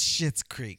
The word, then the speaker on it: Schitt's Creek: It's not spelled that way. It's spelled S-H-C-I-T-T-S. – But it's pronounced Schitt's Creek.